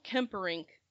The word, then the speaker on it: Kemperink: How do you pronounce it? – It's Cecil Kemperink. K-E-M-P-E-R-I-N-K, Kemperink.